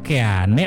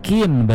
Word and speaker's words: Keane: Pasti yang lagi dengerin di mobil, apa sih ini penyiar kok Keane? Orang jelas-jelas Kin bacanya.